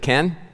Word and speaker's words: Khen: I don't know if I'm pronouncing this right. K-H-E-N, Ken, the Egyptian god Ken. Is it Ken? Yeah, the Egyptian god Ken was the Egyptian equivalent of the Hebrew word Ham.